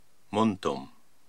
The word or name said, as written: Muntum